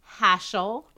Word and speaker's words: Hashel: So you could do Hashel, H-A-S-H-E-L. That rhymes with Dashiell, mm-hmm.